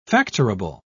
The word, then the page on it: factorable: British English